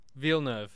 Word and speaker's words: Villeneuve: How do you say Villeneuve? Is it Villeneuve? – Villeneuve. – Okay, I'm pretty happy with that.